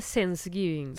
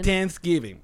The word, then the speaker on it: Thanksgiving: La mamá de Marco, este fin de semana es el Tengeven y, hay gente que le, dice Sensegiving. – Sensegiving. Sensegiving. Que eso no era una serie, Sense8. – Es que tratas como de pronunciar la TH con Sensegiving. – Thanksgiving.